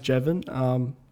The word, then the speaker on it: Jeven: My name's Jeven. Seven with a J, if you want to spell it.